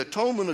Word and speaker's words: atonement: I see, the atonement of Jesus, you can really understand it better when you pronounce it at-one-ment, because that's what happens in the atonement, is that we become one with God in that relationship.